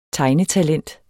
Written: tegnetalent

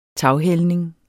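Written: taghældning

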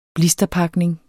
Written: blisterpakning

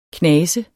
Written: knase

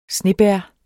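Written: snebær

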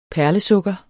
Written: perlesukker

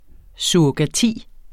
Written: surrogasi

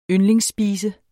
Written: yndlingsspise

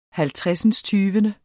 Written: halvtredsindstyvende

